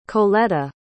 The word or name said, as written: colletta